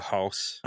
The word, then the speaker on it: Haas: Is it Haas or House? – I think it is the former, like a Haas.